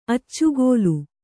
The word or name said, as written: ಅಚ್ಚುಗೋಲು